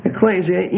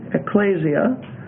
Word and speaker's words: ekklesia: Ecclesia, E-K-K-L-E-S-I-A. That second E is a long E, ecclesia.